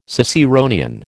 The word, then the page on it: Ciceronian: American English